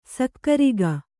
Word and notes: ಸಕ್ಕರೆಗ: sakkarega

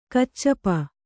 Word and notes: ಕಚ್ಚಪ: kaccapa

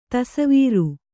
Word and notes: ತಸವೀರು: tasavīru

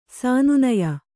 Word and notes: ಸನ್ನಾಯ್ಯ: sannāyya

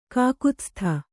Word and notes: ಕಕುತ್ಸ್ಥ: kakutstha

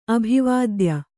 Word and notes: ಅಭಿವಾದ್ಯ: kannada

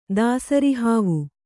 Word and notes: ದಾಸರಿಹಾವು: dāsari hāvu

